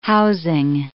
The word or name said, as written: housing